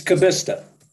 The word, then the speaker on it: Kubista: It's Kubista.